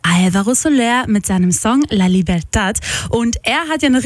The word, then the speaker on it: La Libertad: La Libertad.